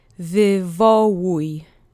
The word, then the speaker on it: wywołuj: The accent goes on the penultimate syllable, so repeat after me three times. Wywołuj, wywołuj, wywołuj.